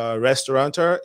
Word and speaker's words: restaurateur: She is one of the up-and-coming chefs, you know, restaurateur. Is that the correct pronunciation? – Restaurateur, yes.